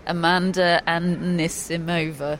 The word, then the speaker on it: Amanda Anisimova: This day forward, David, we will correctly call her Amanda Anisimova.